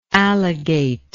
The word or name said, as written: alligate